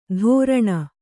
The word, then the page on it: ಧೋರಣ: kannada